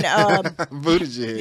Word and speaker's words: Buttigieg: On Buttigieg.